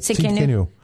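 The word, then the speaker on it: Tsidkenu (צדקנו): Jehovah Tsidkenu, right? I don't think I pronounced that right. How do you pronounce it in Hebrew? – Yeah, that's it. Tsidkenu.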